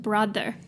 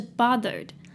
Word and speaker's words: bothered: 这边这个 bothered 没有 r, 所以不要念成 brother, OK, 是 bothered,